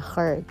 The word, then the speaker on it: heard: 那 hear 是它的现在式原型然后 heard 加上一个 d 发音不太一样哦要念 heard 听到